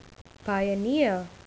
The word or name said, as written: pioneer